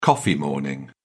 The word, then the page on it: coffee morning: British English